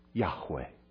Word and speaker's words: Yahweh: Well, it's comprised of four consonants, YHWH. We pronounce it Yahweh.